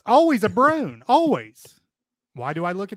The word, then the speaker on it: Broan: By the way, I think when it comes to bathroom fans and exhaust fans, I think Brone makes 93.7% of all bathroom fans. – Spell that. – B-R-O-A-N. – B-R-O-A-N, Brone. – Yeah, Brone.